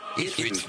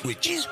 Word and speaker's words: Ipswich: ¿Cómo se dice? – Ipswich. Ipswich. Ipswich.